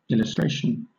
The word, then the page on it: illustration: British English